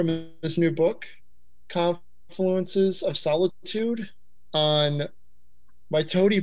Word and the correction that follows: Mitote: Press. – Mitote. – My tote.